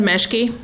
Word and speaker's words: Meschke: Mr. Chair, members of the committee, my name is Linda Meschke. That's spelled M-E-S-C-H-K-E for the record.